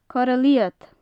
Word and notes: corra liod: gaelic

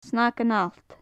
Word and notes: snagan allt: gaelic

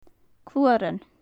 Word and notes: cluaran: gaelic

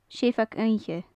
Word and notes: seabhag-oidhche: gaelic

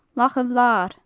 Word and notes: lach a bhlair: gaelic

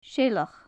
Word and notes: seileach: gaelic

